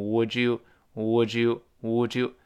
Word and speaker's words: Would you: 你想吃什么样的中餐？Would 跟 you 我们多次说过，读成 Would you？Would you？Would you？